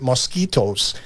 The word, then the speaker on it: moschutos: Yeah, I say moschutos. – Okay. Rose, mallow. I've also heard it mosquitoes, but that's too close to mosquitoes.